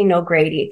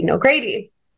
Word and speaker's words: Nogrady: Because so I, I go by the moniker Lady Nogrady because it rhymes. It's easy to say. It's my last name. So 11 years ago, I released that album under the moniker Lady Nogrady.